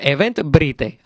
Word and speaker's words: Eventbrite: Event Bright. Se escribe B-R-I-T-E. – Muy bien. – Event, vamos a decirlo en español, Event Brite. Así mismo usted lo escribe.